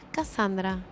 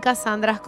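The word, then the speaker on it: Kassandra: Cassandra, alto nombre, baby.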